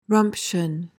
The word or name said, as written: rumption